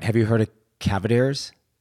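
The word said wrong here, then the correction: Cavender's: Did I say that right? – Cavenders. – Cavenders.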